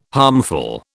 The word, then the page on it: harmful: British English